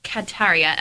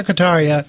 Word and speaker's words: Kataria: Dr. Kataria. – You said it right.